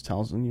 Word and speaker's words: Towson: Towson. Yeah, I think it's Towson. – Towson. – Towson. Oh, there's no N.